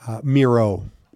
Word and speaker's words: Mierau: Rhymes with hero. – Reggie, how did you pronounce it? – Mierau. – Yeah. – So it's not French. It's actually Dutch background. So that's why it's not EAU. It's not French. It's actually German Dutch background.